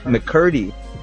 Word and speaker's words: McCurdy: Jenny or Jeanette McCarty, she's most famously known for, I have to bring up her name again because I'm probably mispronouncing it. She's most known for iCarly. It's a show on Nickelodeon. You familiar with her names? I'm sorry, Jeanette McCurdy.